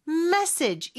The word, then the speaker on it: message: 메시지는 message 이름절의 강세가 있고요.